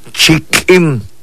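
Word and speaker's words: Chik'in: Chikim. Chikim. Escuche cómo se pronuncia. Chikim. Este es Lakim.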